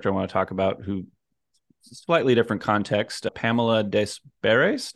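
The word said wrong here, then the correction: Pamela Des Barres: Did I say that right? – Pamela Desbar, yeah. – Yep, Pamela Desbar.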